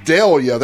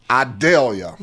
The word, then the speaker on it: Idalia: That's the way we say it in the South. – That's not the way they pronounce it on the Weather Channel. – In the Weather Channel, they said Idalia. – Yeah. – But that ain't how we say it in the South, bro.